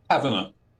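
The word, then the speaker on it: Tavener: Is it John Taverner? – Taverner. – Yeah, yep.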